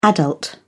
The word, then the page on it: adult: British English